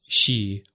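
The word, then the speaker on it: shi (四): You know, four is an unlucky number in Japan because four is pronounced shi, which sounds like shin, which means death.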